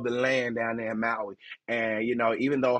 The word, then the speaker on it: Maui: That's how you say it, Maui, Hawaii, the island of Hawaii that went through that wildfire.